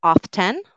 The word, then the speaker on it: often: So is it often or often? Often or often? What do you think? Perfect. So a lot of you got this one right. So the T is silent here. Silent T.